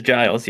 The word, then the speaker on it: Giles: Giles is helping her, and am I saying his name right? I think I always like say it's Giles, right? – It is Giles, yes. – Giles, excellent, thank you.